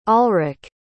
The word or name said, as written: alrik